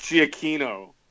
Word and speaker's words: Giacchino: Also, then, while we're at it, it's Giacchino.